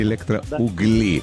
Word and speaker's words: Электроугли: Вот, электроугли и электроугли. Да. Пытались мы разобраться, если что, ударение на «у» электроугли, а жители города электроугли как зовутся, стало быть? – По мне, так электроуглята, я не знаю.